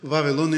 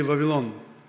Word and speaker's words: Вавилон: Някои слагат дума, ударението на различни Вавилон или Вавилон.